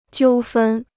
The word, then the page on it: 纠纷: jiū fēn